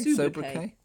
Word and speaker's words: sobriquet: How do you say that? Sobriquet? – Sobriquet. – Sobriquet. Thank you.